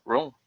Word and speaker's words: ROM: Rome? How do you say it? Rome, that's right.